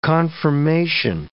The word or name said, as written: conformation